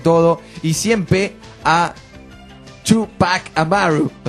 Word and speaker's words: Amaru: Amaru sin acento en la U, Tupac Amaru, dice Esteban. Me pasa el dato, te lo digo yo que soy peruano.